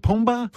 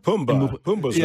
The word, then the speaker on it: Pumbaa: You co-starred in Dumb Money, and then you were Pumbaa. How do you say it? Pumbaa. – Pumbaa's right.